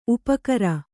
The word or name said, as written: ಉಪಕಾರ